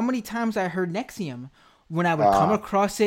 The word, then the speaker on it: NXIVM: I would call it Nexium. I have it spelled out Nexium, NEXIUM. So I would remember to call it that.